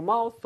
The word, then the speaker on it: mouth: Mouth 할 때 TH 사운드, right? 윗니하고 아랫니 사이에 혀가 살짝 나온다는 느낌. 근데 혀의 위치보다도 바람이 나오는 게 중요합니다.